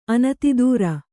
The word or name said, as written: ಅನತಿದೂರ